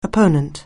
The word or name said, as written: opponent